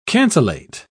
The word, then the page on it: cancellate: British English